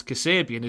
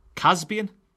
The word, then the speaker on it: Kasabian: I had an English language teacher at A level who used to pronounce Kasabian as in the band Casbian.